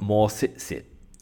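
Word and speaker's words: Maw-sit-sit: Virgo, you have got more sit-sit. More sit-sit, spelled M-A-W, then S-I-T, S-I-T, but the T's aren't normally pronounced when you talk about the crystal.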